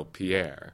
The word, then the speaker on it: Pierre: My mother grew up in Pierre, South Dakota, which is spelled the way you would spell Pierre. – Right. – And it's pronounced Pierre.